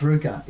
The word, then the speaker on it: RVCA: Is it RV or RVCA. RU? – It's pronounced Ruka, yeah.